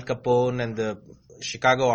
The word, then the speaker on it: Chicago: Chicago.